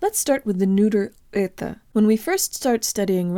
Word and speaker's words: это: Это. Это. Это. Tell your brain the O represents a schwa vowel and make sure to de-emphasize the last syllable.